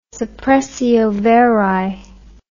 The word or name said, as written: suppressio veri